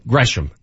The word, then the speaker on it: Gresham: Gresham, that's the proper pronunciation.